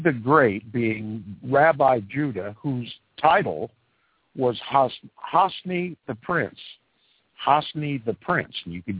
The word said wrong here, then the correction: HaNasi: do your own research on this, and I'll spell it for you if you care to. It's H-A space N-A-S-I hyphen T-H-E space Prince. Hanasi the Prince.